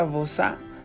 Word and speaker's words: Bauza: Este juego lo hizo Antoine vos se, escribe literal B-A-U-Z-A. Antoine Bausa. Que igual supongo que estando en México está bien pronunciado. Yo tengo la firme convicción de que se, de que se pronuncia Bosa.